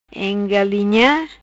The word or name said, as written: Engalinha